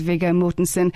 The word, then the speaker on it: Viggo Mortensen: She says, could you channel my apologies to Jason Isaacs as I rudely interrupted a conversation between him and, here's another pronunciation, is it Viggo or Viggo? – Viggo Mortensen. – It is Viggo Mortensen.